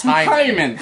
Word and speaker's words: Haman: Like Haman was like some evil dude. – It's pronounced Hyman. – Hyman. Hyman. It's from the throat.